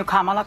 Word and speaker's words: Kamala: Kamala, and I'm calling you Kamala because for all of those who are mispronouncing it, I want you all to know how to say it.